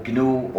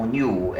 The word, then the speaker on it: GNU: Se puede pronunciar o GNU o GNU en castellano.